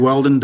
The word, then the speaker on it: Whelden: It's Craig Weldon, W-H-E-L-D-E-N. People often misspell it and spell it with a D-O-N, so make sure you spell the name right.